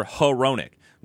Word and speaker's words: Hronek: I heard, I'm pretty sure it was the Red Wings broadcast, and it was a very clear Hronek, which I was like, kind of made me go, what? That's how you say it?